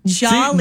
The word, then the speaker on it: Jollibee: Jollibee.